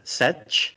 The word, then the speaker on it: Sech: Then you have the Sex Empire. Oh, maybe I said that wrong. It's the Sech, S-E-C-H, Speaking of plowing fields.